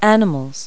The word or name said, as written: animals